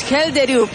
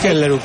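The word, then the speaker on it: Schjelderup: Yo diría con la 21, Andreas es el nombre y el apellido es Helderup. – Es Helderup.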